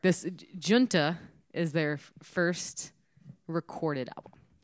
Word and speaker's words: Junta: Hard J, soft U. Junta. Yeah, so that is how you say it.